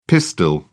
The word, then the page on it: pistil: British English